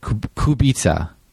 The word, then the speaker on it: Kubica: Yes, it's Robert Kubica.